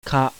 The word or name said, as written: KA